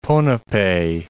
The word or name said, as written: Ponape